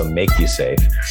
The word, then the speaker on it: MakuSafe: We put the line over it, the Macrone, as it's called, to give it the long A sound. So Make You Safe.